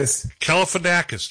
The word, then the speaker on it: Galifianakis: Kalifianakis.